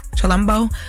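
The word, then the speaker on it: Chilombo: Chalumbo.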